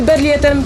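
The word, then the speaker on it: Berliet: No właśnie tu najpierw wyjaśnię Państwu, mówię nazwę po polsku, bo nie spodziewam się, żeby ktokolwiek w Polsce wymawiał ją po francusku, więc jedziemy Berlierem PR100.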